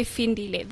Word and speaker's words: Phindile: People couldn't really pronounce my name, Phindile, you know? In South Africa, they say Phindile.